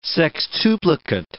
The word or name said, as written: sextuplicate